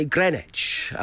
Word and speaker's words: Greenwich: Well, as someone from there, the correct way of pronouncing it, as both Rob Beckett and I can testify for, is Greenwich. So it's G-R-I-N... I-T-C-H. It's Greenwich. It's Greenwich.